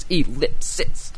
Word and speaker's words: ellipsis: That's awesome, that word has three syllables. Ellipsis.